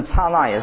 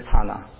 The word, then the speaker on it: 刹那: 因为它这个梵文的发音是 KSANA，就是刹那。 OK，我以前念的有时候念刹那，现在晓得不是念刹那，是要念刹那才对啊。